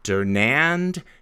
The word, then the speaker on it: Dernand: The accent's on the A, so I would say Dernand, just like you did.